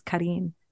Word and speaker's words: Karin: My sister and my brother call me Colleen because my name is Karin.